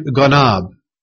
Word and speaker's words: ganab: It's the Hebrew word ganab. Ganab. It's spelled G-A-N-A-B.